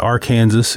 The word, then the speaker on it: Arkansas: the Ark River, Arkansas, or the Arkansas if you're from Kansas. There's a debate on how to pronounce it.